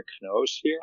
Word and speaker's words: noosphere: Is ESR, the guy who wrote Cathedral on the Bazaar, has an essay called Homesteading the Kno-sphere, which is spelled N-O-O sphere, S-P-H-E-R-E.